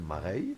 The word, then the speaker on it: Mareye: Donc ça s'écrit M-A-R-E-Y-E, donc c'est Mareille.